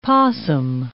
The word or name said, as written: possum